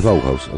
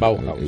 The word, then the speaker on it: Bauhaus: Al que me pide la pronunciación es Bauhaus, así como suena. – Bauhaus.